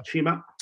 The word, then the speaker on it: Cima: I will call this the C show because maybe a cheesy reference to cannabis, because C3 Industries and Chima is spelled with a C, which I learned, C-I-M-A, yeah. Chima. So very interesting, articulate men.